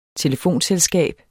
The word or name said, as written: telefonselskab